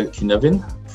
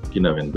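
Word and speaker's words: Cynefin: So in a sense, do you know the Kinevin framework, right? I think it's spelled as Seinfin, but it's like, I think it's Welsh for Kinavin.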